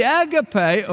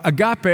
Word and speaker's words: agape: The agape, or agape as some pronounce it, is not that self-seeking love.